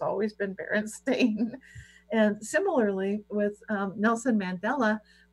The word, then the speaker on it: Berenstain: Yeah, it was Berenstein for me. It right. Right. And now apparently you could never pronounce it that way because apparently it's always been Berenstain.